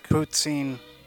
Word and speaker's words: poutine: It's poutine.